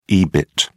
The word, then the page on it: ebit: British English